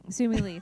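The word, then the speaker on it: Simu Liu: She should be dating Simu Lau. – Yes, she from or Lau? Lee? Sorry. Lee? I'm sorry. If we're saying his name wrong, he's... – Simu Lee. – Simu Lee. Okay.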